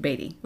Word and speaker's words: Beattie: Now, yes, Dr. Ed and I were mispronouncing it as Beatty. – Yes, because it's spelled B-E-A-T-T-I-E. – Yes, but it's pronounced like Beatty, like Warren Beatty. – Warren Beatty. – Yes. Spelled differently, pronounced similarly.